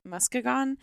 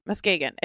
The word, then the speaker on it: Muskegon: Muskegon. He's just making this up. – I don't think that's how it's pronounced. – Why don't you look it up? – Muskegon. It sounds like a dinosaur. – Muskegon County Prosecutor.